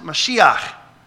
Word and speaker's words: Mashiach: They knew that God was going to send them a king from David's line, an anointed one, which in Hebrew is pronounced Mashiach and is translated in Greek as Christos, which is where we get our English word Christ from.